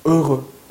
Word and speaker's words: heureux: 행복한 이라는 뜻의 형용사 heureux, heureux, heureux 발음 좀 어렵게 느껴질 수 있는데요. 입 모양이 중요해요. heureux, heureux. 입 모양 바꾸지 않고요. heureux, 네, heureux 이거예요.